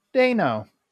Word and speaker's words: Dano: And fun fact, he pronounces his last name Dano. – Paul, I'm sorry, Paul Dano.